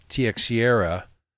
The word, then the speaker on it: Teixeira: Former DNI, Defense National Intelligence, Kash Patel confirms that this kid, Jax Teixeira, and I've heard it pronounced like five different ways. So it's T-E-I-X-E-I-R-A, Teixeira. So I'll just call it, well, that's how I'm going to pronounce it.